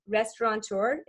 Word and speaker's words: Restaurateur: Restaurateur. Is that how you say it? – Yeah.